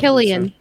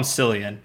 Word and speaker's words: Cillian: So, Killian. Killian. – I'm gonna call him Cillian because Cillian. I'm an American. I don't pronounce things that way. Okay. Yeah, the K. As an American, I have my right to pronounce everything incorrectly.